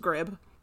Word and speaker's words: grebe: It's spelled G-R-E-B-E, but Wikipedia said it's pronounced grib.